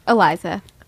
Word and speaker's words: Eliza: Eliza.